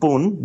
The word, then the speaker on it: Bun: Two words, Shibuya, which is the name of my favourite suburb in Tokyo, and Bun, which is B-U-N, which is my nickname in Japanese.